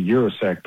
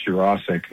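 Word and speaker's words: Yurosek: And then, you know, Ben Jurosek, who used to be Jurosek, but now it's Jurosek. He apparently, you know, got the pronunciation correct.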